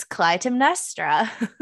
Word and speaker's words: Clytemnestra: It's Clytemnestra by Costanza Casati.